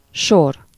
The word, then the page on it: sequence: American English; /ˈsikwəns/; /ˈsikwɛns/